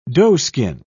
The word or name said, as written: doeskin